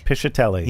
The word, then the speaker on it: Piscitelli: Piscitelli.